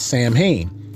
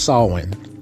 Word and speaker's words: Samhain: And I was one of those people one time ago where I used to say that, but it's actually pronounced Samhain. And I pronounced it that way last year, Samhain, because that's its actual way to say it.